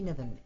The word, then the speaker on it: Niven: Shanti Niven, N-I-V-E-N.